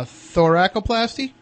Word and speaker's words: thoracoplasty: thoracoplasty is that correct is that am i pronouncing that correct? Yeah, i believe you pronounced it right